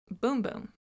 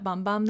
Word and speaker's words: Bum Bum: Apparently, in Brazil, it's pronounced boom boom. I never knew. I'm still going to call it Bum Bum, though, because everybody calls it Bum Bum Cream, and I would feel really weird if I called it the other.